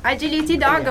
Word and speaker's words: Agility Dog: Agility Dog, ho detto bene? – Sì, Agility Dog, macinofilia.